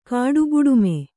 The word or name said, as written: ಕಾಡುಬುಡುಮೆ